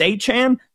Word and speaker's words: Achane: And then all of a sudden, you know, they got this other guy, Devon Achane. How do we say his name? We don't know. Nobody knows. Oh, it's Achane.